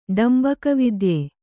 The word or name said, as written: ಡಂಬಕವಿದ್ಯೆ